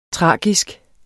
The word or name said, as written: tragisk